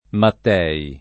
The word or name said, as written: Matthey